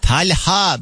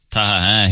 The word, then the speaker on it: Talha: Talha doğru telaffuzdu tabii ama mesela sana Talha dedikleri zaman böyle sen şey yapıyor musun? Talha değil, Talha diye düzeltiyor musun? – Ya çoğunlukla zaten şöyle söyleyeyim, Taha diyorlar. – Taha, he, hiç oralarda bile yoklar.